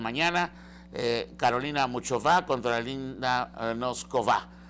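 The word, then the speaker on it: Nosková: en este caso Linda Noskova, más bien, Noskova, está pronunciado así mejor,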